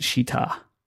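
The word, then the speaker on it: Schiit: This is how it's spelt, but it's actually pronounced Shita, not like the naughty word, which I will not say.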